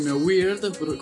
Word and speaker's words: weird: Youngblood con Weird, correggo Giacomo sulla pronuncia, che significa strano.